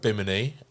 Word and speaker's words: Bimini: Bimini? – Yeah, Bimini.